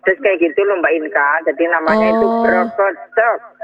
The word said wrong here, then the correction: berkecek: Oh, berkecek ya, bacanya berkecek ya.